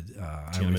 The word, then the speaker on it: Tiananmen: And then, you know, you have a revisitation of what is the Tiananmen Square. – Yes. I can never remember how to pronounce it. Tiananmen Square.